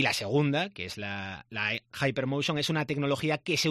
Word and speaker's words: Hypermotion: Esto hay que pronunciarlo bien, además, porque hemos hecho un curso, ¿eh?, para esto. No es Hypermotion o algo, no, es Hypermotion, la Liga Hypermotion.